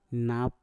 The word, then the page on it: nap: Mpur